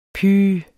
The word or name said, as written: pyh